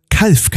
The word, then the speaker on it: Calw: Es ist auch so, dass viele Ureinwohner hier das Ganze noch als Calw aussprechen, also wie eine kleine Kuh. Die besonders positiv eingestellten Leute hier sagen gern, aus diesem Calw wird die eine Kuh, ist viel zu klein, der Stall dazu.